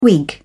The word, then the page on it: whig: British English